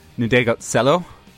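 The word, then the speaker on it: Ndegeocello: They bring in a neo soul musician who's Michelle Ndegotselo.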